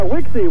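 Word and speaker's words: WIXY: Well, I mean, like Wixie? Is that how you say it? – Yeah, Wixie, 100.3.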